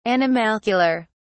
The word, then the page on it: animalcular: British English